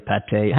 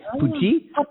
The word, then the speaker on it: pâté: And before that, I had vegan curry, tofu, and tapé or pâté. How do you say pâté? – Pâté. – What's it called? – Pâté? We say it as pâté.